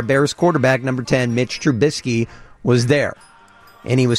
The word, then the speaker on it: Trubisky: They can't pronounce his last name. Mitch Trubisky. The biscuit. It's Mitch Trubisky.